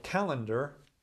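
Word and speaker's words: Callander: This one's called Calendar, not spelled like the one, the Roman calendar that we use to mark the days. It's C-A-L-L-A-N-D-E-R, a place in Scotland.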